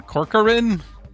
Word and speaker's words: Corcoran: Barbara Corcoran. Corcoran? Is that how you say it? – Yeah.